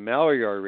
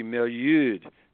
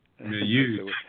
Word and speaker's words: Maillard: Well, I just didn't pronounce it correctly because it's really a French word. And I was giving a lecture. I was giving a lecture a couple of years ago, and I said the Maillard reaction. And this Frenchman in the back of the room says, "Monsieur, it is the Maillard." – Maillard. – So that's the way it should be pronounced.